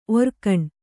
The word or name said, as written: ಓರೆಗಣ್ಣು